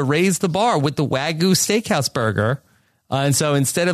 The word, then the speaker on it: Wagyu: it's called the Wagyu, W-A-G-Y-U.